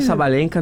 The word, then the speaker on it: Sabalenka: Arina Sabalenka, número 1 do mundo de Wattia. – Ah, é assim que se pronuncia.